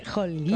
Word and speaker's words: Holguín: Holguín. – Con la H muda. Que soy española. – Estos apellidos es que son complicados. – Holguín.